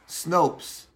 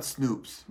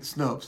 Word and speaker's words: Snopes: I hope I'm saying it right. Snopes. It's not Snoops, right? Snopes. Thank you. S-N-O-P-E-S.